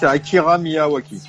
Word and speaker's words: Akira Miyawaki: Akari Miyawaki. – Miyawaki. Ouais, désolé pour la prononciation pour les japonais. Je me trompe de... Non, je crois que c'est ça, non? C'est pas... – C'est Akira Miyawaki.